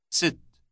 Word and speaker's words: ست: Sit. – It rhymes with the English word sit.